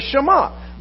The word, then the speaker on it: Shema: Because it's the listen, it's the hear, which in Hebrew is pronounced the Shema.